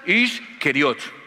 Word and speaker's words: Iscariote: Su nombre sería Judas, el de Keriot, que se pronunciaría Judas Iscariot. Y Judas Iscariot es lo que nosotros pronunciamos hoy como Judas Iscariote, para diferenciarlo.